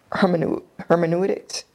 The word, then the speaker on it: hermeneutics: And that goes back to hermeneutics. Am I saying that right? – Mm-hmm.